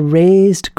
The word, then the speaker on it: rased: And then he says that Savitri has to use her soul's force to dislodge her past and make a raised ground to shape anew her fate. It sounds like the word to raise, to lift up, but it's spelled R-A-S-E-D.